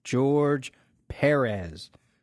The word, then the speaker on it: George Perez: From the man himself, his name is pronounced George Perez. Not George Perez, George Perez. Accent on the first E.